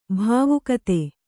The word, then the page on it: ಭಾವುಕತೆ: bhāvukate